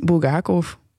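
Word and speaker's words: Bulgakov (Булгаков): Eh, dus ik dacht, ik, ik, dat zal wel voor uh, Bulgakov ook gelden, zoals jullie dat eh, noemden. Dus heb ik mijn Russische oom opgebeld om te vragen hoe spreek je dit uit. En die zei Bulgakov.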